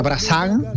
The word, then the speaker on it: Brassens: Eso es, antes de la Penélope de Serrat y de Algueró, está la Penélope de Brassens, se escribe Brassens, pero se pronunciaría Brassens, y hay que leer siempre fino, tú sabes que aquí tratamos de hilar siempre fino, sí.